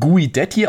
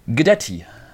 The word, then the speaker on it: Guidetti: Vielen Dank für die Grüße, ähm, der uns gesagt hat, ein leicht stummes U und ein komplett stummes I. So wird der Mann richtig ausgesprochen. Also mehr so ein Gedetti.